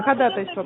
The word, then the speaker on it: ходатайство: Ходатайство правильно говорить, во-первых.